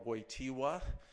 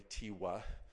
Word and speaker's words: Wojtyła: So, and among also Patriarch Maximus Saig, and then Archbishop Karol Wojtyła, that's the way to say his name in Polish, by the way, Wojtyła,